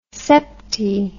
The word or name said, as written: septi